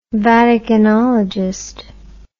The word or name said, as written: Vaticanologist